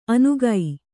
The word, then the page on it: ಅನುಗೈ: Kannada